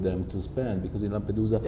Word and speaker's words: Lampedusa: Lampedusa.